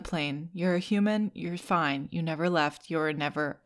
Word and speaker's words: Fiennes: F-I-E-N-N-E-S. Rafe, fine.